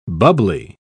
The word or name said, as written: bubbly